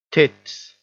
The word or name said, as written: tits